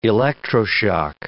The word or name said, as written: electroshock